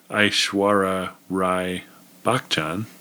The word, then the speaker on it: Aishwarya Rai Bachchan: Well, one other interesting detail as I'm looking at the IMDb page for Aishwarya Rai Bachchan. Am I saying that correctly? – Mm-hmm.